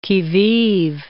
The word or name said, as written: qui vive